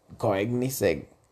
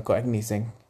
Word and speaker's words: Koenigsegg: Como por ejemplo, últimamente he estado viendo a un chavo que se llama eh, Don Koegnisek no sé cómo se pronuncia, Don Koenigsegg. Don Koenigsegg.